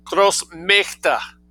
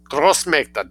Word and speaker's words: Großmächte: So, Großmacht, great power, Großmächte, A umlaut, but the same spelling, M-A with an umlaut, C-H-T. Großmächte, die Großmächte, D for plural, D-I-E, plural, German, D.